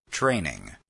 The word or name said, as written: training